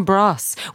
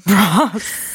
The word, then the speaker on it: Bros: And then, yeah, landing on Bross, which like, again, is bros, but pronounced Bross.